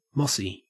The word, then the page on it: mossy: Australian English